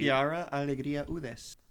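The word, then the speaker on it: Quiara Alegría Hudes: Chiara Alegría Udes. – There you go.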